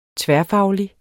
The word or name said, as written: tværfaglig